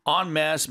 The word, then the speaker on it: en masse: En masse means all together or in a large number. Mm-hmm. And when you're pronouncing it, it's spelled E-N-M-A-S-S-E, two words, but it's pronounced en masse, not en masse.